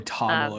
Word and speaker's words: Toggl: So it's spelled T-O-G-G-L and then track. – Oh, toggle. Okay. They hold the E off.